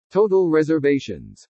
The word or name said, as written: TOTAL RESERVATIONS